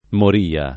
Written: moria

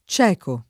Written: cecare

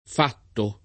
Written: fatto

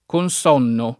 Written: Consonno

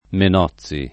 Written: Menozzi

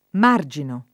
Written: marginare

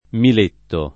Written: Miletto

